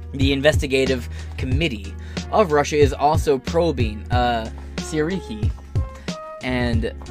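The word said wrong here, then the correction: Syrskyi: It's Sirsky. That's how I'm going to pronounce it.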